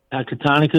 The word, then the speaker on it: Pecatonica: Is a first-time caller, and he is in Pecatonica, Illinois. I hope I said that right. Hello. Thanks for calling. – Pecatonica is correct.